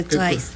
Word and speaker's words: Twice: Que nos diga cuál es su canción favorita de Twice. A ver, si lo dije bien, ¿vale? – Sí, claro, de Twice.